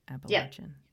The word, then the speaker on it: Appalachian: Am I saying it right? Appalachian. – Yeah. Okay. You are.